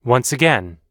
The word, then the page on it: once again: American English